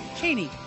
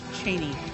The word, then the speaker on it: Cheney: Is it Cheney or Cheney? – Cheney. – You sure about that? – Yes. – Because it seems like I hear people pronounce it both ways. – Well, it's pronounced Cheney.